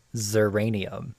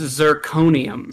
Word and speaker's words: zirconium: I'm going to say zeranium. – No, it's zirconium. – Zirconium. Man. See, I was there with the first one syllable.